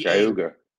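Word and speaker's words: Geauga: There is one that was, I'm going to probably pronounce this wrong, Guaga Lake. It's G-E-A-U-G-A. – Cayuga. – Cayuga? Okay. – Cayuga. – Cayuga. Say it again. – Cayuga Lake.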